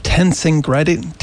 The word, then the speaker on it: tensegrity: There's a term that it's a difficult to pronounce one, but it's tensegrity. I can't even pronounce it myself. Yes, you got it.